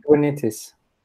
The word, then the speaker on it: Kubernetes: Dünya çapında bayağı bir Kubernetes, ya nasıl okunuyor bu ya? Bilen var mı? – Kubernetes. – Kubernetes diye galiba.